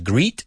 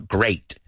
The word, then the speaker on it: great: Cuidado, la pronunciación no es greet, sino great. Me acuerdo con los niños el otro día, dijeron algunos greet. Y no es greet, sino great. Suena como ocho, ¿no? Rima con ocho.